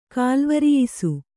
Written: ಕಾಲ್ವರಿಯಿಸು

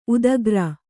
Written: ಉದ್ಗರ

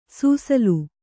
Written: ಸುಸಿಲು

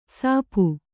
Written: ಸಾಪು

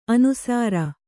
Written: ಅನುಸೌರ

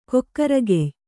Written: ಕೊಕ್ಕರಗೆಯ್

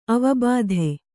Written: ಅವಬೋಧೆ

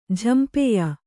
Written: ಝಂಪೆಯ

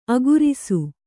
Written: ಆಕರಿಸು

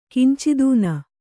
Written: ಕಂಚುತನ

